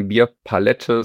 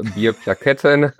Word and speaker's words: Bierplakette: Es ist keine Bierpalette, sondern eine Bierplakette.